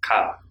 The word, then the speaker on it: Kyle: And it's not Kyle. I just watched this. Kyle? One syllable? Lowry. – Cat. – He was upset. It was... – Cat. – Kyle.